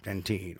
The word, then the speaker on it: dentine: Dentine, which, by the way, dentine is actually the English pronunciation and spelling of the word dentin. That's the way we say that in the United States.